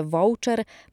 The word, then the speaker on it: voucher: Spolonizowaną i częstą wymowę voucher można więc uznać za poprawną.